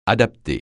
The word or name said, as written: adapter